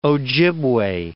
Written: Ojibwa

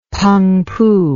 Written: Pengpu

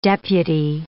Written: deputy

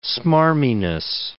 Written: smarminess

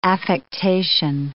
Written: affectation

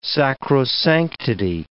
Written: sacrosanctity

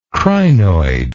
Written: crinoid

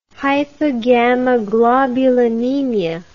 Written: hypogammaglobulinemia

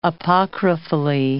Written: apocryphally

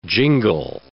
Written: jingle

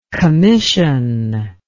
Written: kamyshin

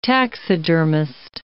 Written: taxidermist

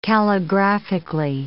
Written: calligraphically

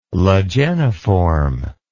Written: lageniform

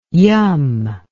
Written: yum

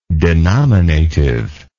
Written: denominatively

